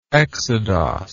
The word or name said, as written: exodos